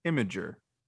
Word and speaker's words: Imgur: Imgur.